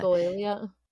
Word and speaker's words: echolalia: Echolalia. – Yeah. Echolalia.